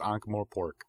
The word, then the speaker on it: Ankh-Morpork: Did I pronounce that right? You're the one who reads the series. – Yeah, I think we went through this last week. But yeah, I'm pretty sure it's Ankh-Morpork.